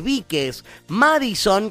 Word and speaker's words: Bikes Madison: Se escribe B alta y latina K-E-S Víquez Madison, como suena Madison con I latina, así como suena Madison, y van a seguirla.